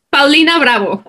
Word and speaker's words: Paulina Bravo: Like full-on Mexican? – Yeah. Go for it. – Paulina Bravo.